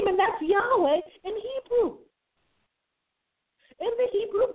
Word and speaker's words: Yahweh: God's name is always translated Lord, L-O-R-D, all caps, in the English Bible. But the Hebrew would be pronounced something like Yahweh.